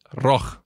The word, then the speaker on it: Roig: A Pamesa pertence a um empresário chamado Juan Roig, ou pelo menos Roig é como se escreve, porque o jeito certo de falar é Roch, que por sua vez é irmão do também empresário Fernando Roch, e o Fernando é o dono do Real.